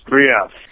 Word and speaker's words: Grief: Yes, Grief.